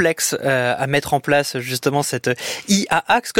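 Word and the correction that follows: IA Act: comme c'est tout aussi complexe d'ailleurs à prononcer, mais ça, Marie Turcan le fait très bien, elle vient de nous rejoindre dans ce studio. Bonsoir Marie. Bonsoir, bonsoir. IA acte, IA acte.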